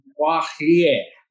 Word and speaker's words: Pohirieth: You know, every teacher back in elementary and in kindergarten, if I can recall correctly, had a hard time pronouncing the Pohirieth, which is my great grandfather's last name.